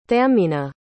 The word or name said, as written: thameena